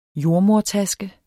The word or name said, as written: JORDEMODERTASKE